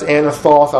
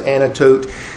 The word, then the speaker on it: Anathoth: He was in a place that I think is pronounced Anathoth. I've also seen it pronounced Anatote.